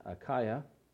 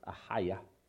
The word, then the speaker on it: Achaia: when he had passed through Macedonia and Achaia, it's actually pronounced Achaia, to go to Jerusalem, saying, after I've been there, I must also see Rome.